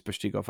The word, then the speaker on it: Peshtigo: Am I saying that right? Peshtigo? – Peshtigo's Wisconsin, yeah.